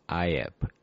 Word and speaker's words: Eype: From Egypt, Dorset. Wow, so this is spelled... No, it's not Egypt. It's Iep. E-Y-P-E.